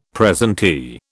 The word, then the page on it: presentee: British English